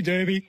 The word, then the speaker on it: Derby: So I just say them both now, Derby, Derby, whatever you want to pronounce it.